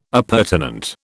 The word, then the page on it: appurtenant: British English